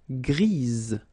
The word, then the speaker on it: grise: Gris, don't pronounce the S, but then for the feminine form, grise. Remember? Grise. All right? So when you add this E, basically it gives you the pronunciation or the possibility to pronounce the previous letter here. It's z, grise.